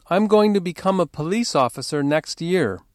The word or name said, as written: next year